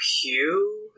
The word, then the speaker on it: Pugh: We have Florence Pugh, if that's how you say it? – Yeah.